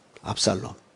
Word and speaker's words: 압살롬: 압살롬.